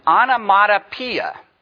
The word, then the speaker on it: onomatopoeia: It is an example of something we call an onomatopoeia. I actually said it. Onomatopoeia. If you're a visitor, that would not strike you, but everybody else should almost faint that actually pronounced the word correctly.